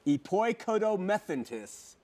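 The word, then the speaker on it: epoikodomethentis (ἐποικοδομηθέντες): If you look at the Greek word here, which is epoikodo, excuse my pronunciation, epoikodomethentis, that's how you say it, okay?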